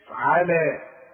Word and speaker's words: على: طب هي مكتوبة بالياء تكتب بالياء وتنطق بالالف على خلاص.